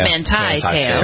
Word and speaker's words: Manti Te'o: He says they were to blame for low ratings for the second part of an interview he did with Manti Te'o. Is that how you pronounce his name? – Yeah. Manti Te'o.